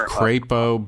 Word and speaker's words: Crapo: It is Crapo, but in our hearts we know it's Crapo.